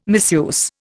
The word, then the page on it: misuse: Indian